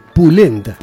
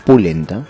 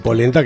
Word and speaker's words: pulenta: Se escribe así, P-U, pulenta. – Pulenta. – Ah, no va a decir polenta, que no tiene nada que ver. – No, pulenta.